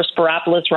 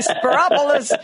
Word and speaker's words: Spyropoulos: And remember, Spyropoulos rhymes with Metropolis. – Okay, Spyropoulos, running for clerk of the Circuit Court of Cook County.